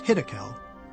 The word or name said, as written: Hiddekel